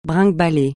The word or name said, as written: bringuebaler